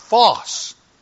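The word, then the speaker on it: phos (φῶς): It's pronounced phos.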